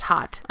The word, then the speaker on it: Haught: Yeah, H-A-U-G-H-T, but it's pronounced hot.